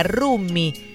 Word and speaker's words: Rummy: Se escribe RUMMY, Rumi. ¿Qué tal?